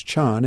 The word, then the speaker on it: Chan: In China, the word Zen is pronounced as Chan, and that's where the tradition developed and flourished in China in the 7th, 8th, 9th, 10th, 11th, 12th century, and so forth.